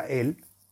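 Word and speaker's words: Él (אל): y se pronuncia Él, y luego Vierta la mezcla ante la puerta de la vivienda de tu enemigo.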